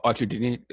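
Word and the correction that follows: Arcidiacono: Archer Diakono. – Yeah, there you go, right there. I knew it sounded right.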